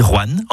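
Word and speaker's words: Rouen: Vous avez remarqué comment je dis Juan en une syllabe plutôt que Rohan en deux syllabes? C'est comme ça qu'on y dit dans la région.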